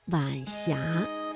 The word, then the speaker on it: 晚霞: 晚霞是晚和阳平字相连，声调呢读二一调，读晚霞。